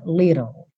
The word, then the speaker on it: little: Então, little, a gente tem esses dois T's soando como rararã, little, little. Depois do rararã, você leva a língua para o céu da boca para fazer o L. E esse E final não é pronunciado, tá?